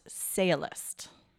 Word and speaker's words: sciolist: And it's actually spelled S-C-I-O-L-I-S-T, but it's pronounced sailist.